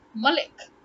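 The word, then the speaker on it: Malick: So it's Malik. Then why do you still say Malik? How does Lick translate to leak? That's Leak or Leek. Leek, right? This is lick. L-I-C-K, lick.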